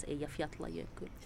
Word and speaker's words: Eyjafjallajökull: Ja, som de facto om jag nu får vara besserwisser så ska det visst uttalas Eyjafjallajökull.